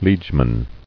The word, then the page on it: Liegeman: liege·man